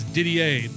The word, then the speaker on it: Didier: That's Didier, but it's spelled D-I-D-I-E-R.